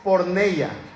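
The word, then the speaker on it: porneia (πορνεία): La palabra es porneia. Porneia. De hecho, así se pronuncia.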